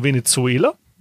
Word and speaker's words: Venezuela: die Venezio, äh, Venezua, die Marine von Venezuela, so spricht man es besser aus, ähm, die der Meinung war, hey, äh, wir möchten mal einen äh, außerhalb der Küstengewässer äh, mal ein Kreuzfahrtschiff angreifen.